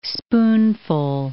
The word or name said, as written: spoonful